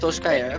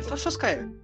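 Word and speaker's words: Solskjær: Solskjaer, é Solskjaer.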